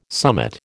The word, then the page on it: summit: American English